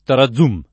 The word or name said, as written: tarazum